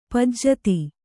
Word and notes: ಪೂಜಿತೆ: pūjite

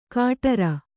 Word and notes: ಕಾಟರ: kāṭara

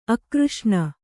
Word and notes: ಅಕೃಷ್ಣ: akřṣṇa

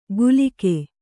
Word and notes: ಗುಲಿಕೆ: gulike

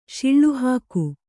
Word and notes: ಶಿಳ್ಳುಹಾಕು: kannada; śiḷḷu hāku

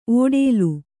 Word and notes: ಒಡಲು: Kannada

